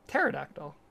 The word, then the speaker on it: Pterodactyl: P is silent, by the way. – Is it? Yeah. That's why we say it that way. Latinum Pterodactyl.